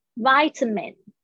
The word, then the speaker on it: vitamin: 接下来是 vitamin，这个呢要注意一下，不是不是 vitamin 哦，不是维他命哦，是 vi，这个是 i 的声音，vitamin，这个 a 呢发 a 的声音，vitamin。 vitamin vitamin OK，所以这几个发音呢有点难。我们这样今天这样子一下子又教了十八个单词，好充实啊 vitamin。